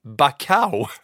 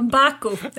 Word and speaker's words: Baku: För det här var nog det bästa. Bacau! Uh, Baku. Jag skulle sagt Azerbajdzjan istället så hade vi sluppit det.